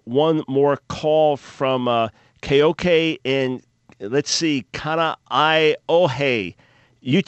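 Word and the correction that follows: Kaneohe (Kāneʻohe): All right, how do you pronounce where you live in Hawaii? – Kaneohe. – Okay, you said it better.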